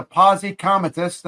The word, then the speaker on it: posse comitatus: All actions must align with the posse comitatus. Did I say it right? Help me out, Gary. Jump in here at any time. – Actually, I think you got it.